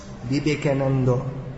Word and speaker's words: Vivekananda: This famous Mayavadi of sorts, Swami Vivekananda, as it's pronounced in Bengali, Vivekananda, he said, it doesn't matter what you eat, it depends on your consciousness.